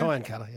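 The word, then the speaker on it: Kyancutta: And she's got a farm in, how do you pronounce it? Is it Kynecutter in South Australia? – Kynecutter, yeah.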